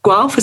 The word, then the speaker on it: Guelph: the University of Guelph. Is that how you pronounce it? Sorry. – That's right. That's right.